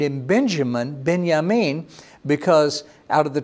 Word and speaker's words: Benjamin: Now, maybe you've heard of the name Benjamin. Well, in Hebrew, you would pronounce that Ben-Yamin.